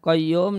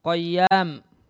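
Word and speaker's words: qayyum (القيوم): Wakana dan adalah Umar membacanya bukan qayyum namun qayyam maka semua makhluk yang ada membutuhkan Allah dan Allah tidak membutuhkan mereka semua.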